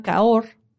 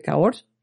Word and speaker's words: Cahors: Abajo de Burdeos se llama Caor eh, la región. Y si tú pruebas un vino de Malbec argentino y de Malbec de Cahors, es muy diferente. Se escribe Cahors, pero es Cahor.